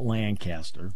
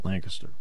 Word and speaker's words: Lancaster: Not Lancaster. Lancaster. This is our state. We have our own pronunciations, which I prefer.